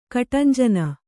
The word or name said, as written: ಕಟಾಂಜನ